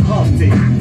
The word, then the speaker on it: Humpty: My name is Humpty, pronounced with the umpty.